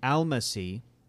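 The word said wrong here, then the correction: Almásy: Is that how we pronounce Ray Fiennes? No, it was a member who was like Almashi or something. – Almashi. – Almashi. – He's Hungarian, remember? – Almashi is given clues by a local...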